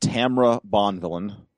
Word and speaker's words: Tamra Bonvillain: I got to meet Tamra Bonvillain.